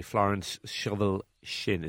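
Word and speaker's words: Florence Scovel Shinn: The Game of Life and How to Play It by Florence Shovel Shinn.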